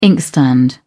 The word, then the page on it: inkstand: British English